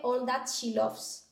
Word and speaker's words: All That She Loves: que es la fundadora de All That She Loves.